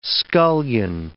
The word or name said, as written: scullion